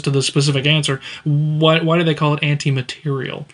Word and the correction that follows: materiel: It's pronounced, it is spelled materiel. – Okay, so two L's and an E? – Yes. – Okay, okay. – I believe. – I can't remember if it has the two L's and E, right. But it is pronounced anti-materiel.